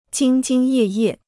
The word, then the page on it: 兢兢业业: jīng jīng yè yè